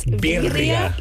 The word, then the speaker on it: birria: You got to roll your R. So if you can, then you got it. – Okay. – It's birria. Yes.